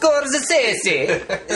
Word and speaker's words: Scorsese: I have a habit of changing it, like, when I hear, like, famous people talking about him, and they say Scorsese. I'm like, oh, it must be Scorsese. – Scorsese. – Oh. It's Italian. – Oh. It's Scorsese. Is that how you say it?